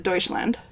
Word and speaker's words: Deutschland: So, Germany they pronounce it Deutschland. – Mm. So I don't know how we get to Germany from Deutschland, but the same with we say Munich and there it's München.